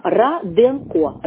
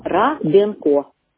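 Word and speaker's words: Раденко: Фамилия пишет, фамилия, значит, пишется через А. Ра-Денко. Ра-Денко.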